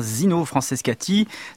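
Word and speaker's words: Zino Francescatti: Et oui, ma consoeur de la chaîne nationale de la radiodiffusion française prononce bien Zino Franceschetti.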